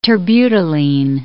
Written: terbutaline

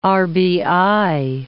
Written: RBI

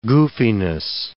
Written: goofiness

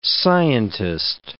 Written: scientist

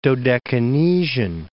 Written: Dodecanesian

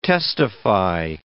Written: testify

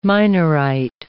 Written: Minorite

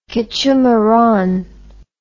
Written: quechumaran

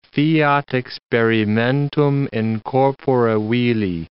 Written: fiat experimentum in corpore vili

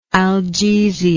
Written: algesia

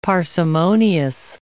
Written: parsimonious

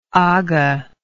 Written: aga

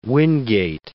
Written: Wingate

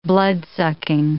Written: bloodsucking

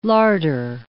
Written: larder

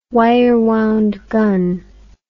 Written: wirewound gun

